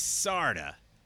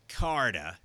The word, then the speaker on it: Carda: Yes, for the last time, it's Sarda, not Carda. Even though it's spelled Carda, C-A-R-D-A, it's Sarda.